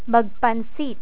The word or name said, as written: magpansit